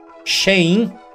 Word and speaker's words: Shein: Se bem que ele já estava envolvido num rolê aí, a treta da Shein, que é o nome certo de se pronunciar. Shein.